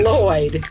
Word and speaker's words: Lloyd: Put your tongue where you're about to say sugar and then instead blow out on the sides. – Say Lloyd.